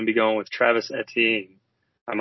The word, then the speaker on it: Etienne: His name is Etienne. – Oh, Etienne. It's like E.T. go home.